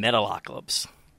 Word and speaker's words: Metalocalypse: from Metalocalypse.